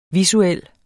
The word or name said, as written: visuel